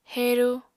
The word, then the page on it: heru: apatani